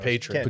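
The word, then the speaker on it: Patrón: Pronounced Patron.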